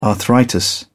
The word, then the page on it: arthritis: British English